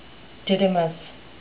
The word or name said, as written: didymous